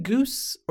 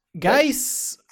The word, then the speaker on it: Guice: Mike Evans, Cooper Kupp, Vance McDonald, Darius, is it Goose or Juice? – Geis. – 0 for 2. – Yeah, you wouldn't think G-U-I-C-E could be Goose, Juice, you just pronounce it. No, it's Geis. Makes no sense.